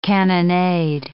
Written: cannonade

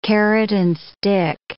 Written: carrot-and-stick

